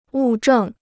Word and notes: 物证: wù zhèng

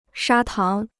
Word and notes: 砂糖: shā táng

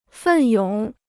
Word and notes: 奋勇: fèn yǒng

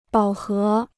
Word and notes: 饱和: bǎo hé

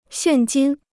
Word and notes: 现金: xiàn jīn

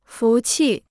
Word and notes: 服气: fú qì

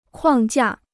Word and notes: 框架: kuàng jià

